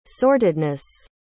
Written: sortedness

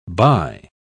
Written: bye

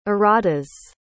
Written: erratas

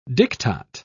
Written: diktat

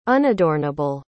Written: unadornable

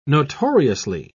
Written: notoriously

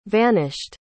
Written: vanished

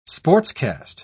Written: sportscast

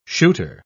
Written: shooter

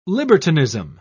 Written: libertinism

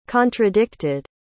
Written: contradicted

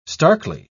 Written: starkly

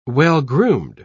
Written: well-groomed

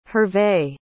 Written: hervey

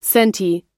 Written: sente

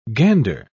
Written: gander

